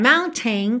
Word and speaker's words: mountain: Mountain é uma palavra que tem duas pronúncias diferentes e as duas são bastante comuns. Uma eu, foi a que eu fiz agora, mountain, com esse tem, tem, tem. E a outra é aquele som para dentro.